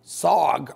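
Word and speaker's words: Sarg: Sog rhymes with Og.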